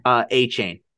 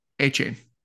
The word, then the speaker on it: Achane: Achane. – Achane.